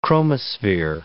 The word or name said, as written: chromosphere